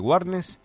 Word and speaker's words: Warnes: Y ellos están en la calle Warnes 299, acá en Capital Federal. ¿Lo dije bien? – Perfecto, perfecto.